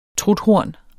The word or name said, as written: truthorn